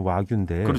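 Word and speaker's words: 와규인데 (和牛): 일본 소를 화우라고 하잖아요. – 그 일본 발음으로 읽으면 와규인데.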